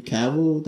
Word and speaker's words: Cavill: And I heard that I'm pretty sure they're bringing back Henry Cavill.